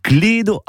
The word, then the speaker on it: clédo: En réalité, ce mot vient de l'occitan clédo, avec un E fermé, mais prononcé à la française, ben ça donne clède.